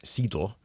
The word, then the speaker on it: cidre: So there have been French-only ciders, which in French it's cidre.